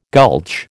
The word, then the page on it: gulch: American English